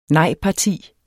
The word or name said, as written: nejparti